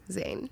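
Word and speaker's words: Zayn: Zane.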